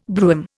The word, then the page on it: brougham: Indian